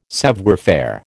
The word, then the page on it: savoir faire: American English